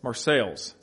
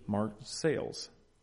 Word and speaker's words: Marseilles: Marseilles. See, I get it wrong. every time. Marseilles. But it's French. You're not supposed to pronounce the L.